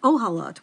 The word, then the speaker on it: Ohalot: And some even suggest that the name of this Masechet should be pronounced Ahilot, which means covering, instead of Ohalot, which means tents.